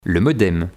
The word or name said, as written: Le modem